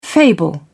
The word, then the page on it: fable: British English